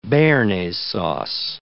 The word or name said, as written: bearnaise sauce